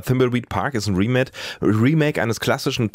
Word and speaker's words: Thimbleweed Park: Das Spiel, um das es aber jetzt geht, heißt Thimbleweed Park.